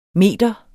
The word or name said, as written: meter